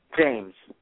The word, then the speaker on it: James: James. – Oh, James, sorry. – No problem, no problem. – So, James, I pronounce the S at the end, so sometimes it's hard for people to understand.